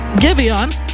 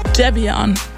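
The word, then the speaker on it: Giveon: Gibeon heißt eigentlich Jevion. – My mom named me Jevion. I don't think I ever told this story. So she named me Jevion, spelled the same way.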